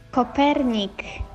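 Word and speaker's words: Kopernik: D'entrada no es deia Copèrnic, perquè era polonès, ella era Nicolaus Copernik, però bé, sentim-ho pronunciat per una noia polonesa que ha tingut, doncs mira, el detall de fer-nos una nota de veu.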